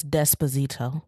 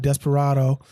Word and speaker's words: Despacito: That's Despacito. – Despacito. I called it Desperado.